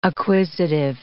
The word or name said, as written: acquisitive